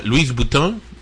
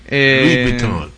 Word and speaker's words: Louis Vuitton: Louis Vuitton. – ¿Lo dije bien? – No. – Louis Vuitton. – No. – Clases de francés. Louis Vuitton.